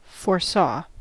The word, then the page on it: foresaw: American English